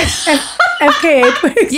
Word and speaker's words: FKA twigs: Fika Twigs? Is that how you say it? FKA Twigs.